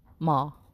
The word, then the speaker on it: Maw: Maul, I believe is how it's pronounced.